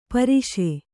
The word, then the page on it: ಪರಸು: Kannada